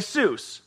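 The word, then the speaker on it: Iesous: The Greek translation of the Hebrew word Yeshua is Iesus. Say Iesus. Iesus. Now, if you take the wrong emphasis on the wrong syllable and switch it around on Iesus, it kind of sounds like Jesus.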